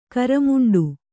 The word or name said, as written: ಕರಮುಂಡು